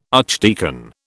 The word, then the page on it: archdeacon: British English